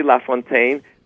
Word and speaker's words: LaFountain: Oh, well, it's just my name, Larry LaFountain. I say LaFontaine, but it's spelled L-A Fountain, like water fountain.